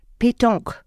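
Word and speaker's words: Pétanque: Sie haben es gerade sehr schön ausgesprochen. Ich habe es dann tatsächlich falsch gesagt. Ne? Mhm. Sagen Sie es nochmal, mhm. damit es die HörerInnen dann auch drin haben. Petanque. Okay, sehr schön.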